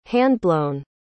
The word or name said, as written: handblown